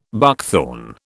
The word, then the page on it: buckthorn: British English